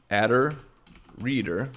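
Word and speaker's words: attr_reader: I pronounce that adder reader. I'm pretty sure a lot of other folks do as well. You can say attribute reader if you want, but just as long as you understand what we're doing here.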